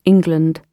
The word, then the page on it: England: British English